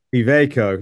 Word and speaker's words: Iveco: Mike Cutts, who is the business line director for UK and Ireland for Iveco. Is that I'm not pronouncing that right, am I? – Iveco.